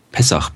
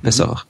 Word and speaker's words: Pessach: wie spricht man das eigentlich Pessach. aus? – Pessach, Pessach. Mhm. Man schreibt ja Passa. Ja? Also zumindest ich habe so geschrieben, P-A-S-S-A-H.